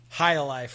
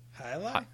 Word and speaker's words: Hialeah: Oh, he could, but it ended up in India somewhere, and he changed the face of some, you know, Hialeah franchise. – Oh, that's how we got our Slack hacked into. – You hanging out with some weird? How do you say that? – Hialeah.